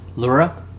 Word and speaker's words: Luera: How do you say that name? – Luera. – L-U-E-R-A. 42 was killed after growing suspicion from Ortiz.